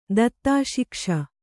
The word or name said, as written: ದತ್ತಾಶಿಕ್ಷ